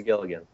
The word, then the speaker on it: Gilligan: I think it is Gilligan.